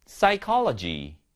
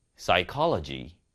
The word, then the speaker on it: psychology: Psy, Psy, Pseudonym, Pseudonym, Pneumonia, Pneumonia, Psychology, Psychology. 자, 피디님, 공통점이 뭐죠? 너무 기습 질문인가요? 자, 일단은 공동점은 P 발음이 전부 다 안 난다라는 것이죠. 그래서 P 다음에 바로 S가 오거나 바로 N이 오면은 무음인 경우가 많습니다.